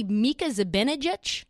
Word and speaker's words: Mika Zibanejad: Actually, Mika Zibanejad. Is that how you say his name? – Yeah, yeah.